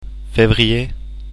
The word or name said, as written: fevrier